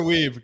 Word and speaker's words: Wiebe: Gunnar Weave. Gunnar Weave. Yeah. Weave. Gunnar Weave. – Spell it. W-I-E or W-E-I? – W-I-E-B-E.